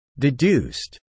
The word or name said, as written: deduced